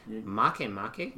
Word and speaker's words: Makemake: Makemake. – Yep, it's spelled make make. It's a real one.